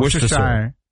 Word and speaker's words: Worcestershire: So you don't say the R? You don't say Worcestershire? – No.